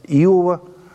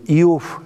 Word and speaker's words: Иов: В наш раздел учительной литературы входит 5 книг от Иова до «Песни песни». Что касается названия книги, мы говорим «Иов» – это славянское произношение, «Иов» – это русское произношение. Поэтому и так правильно.